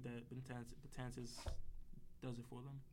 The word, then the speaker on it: Betances: Yeah, Betances, I think is how you pronounce it. – Betances.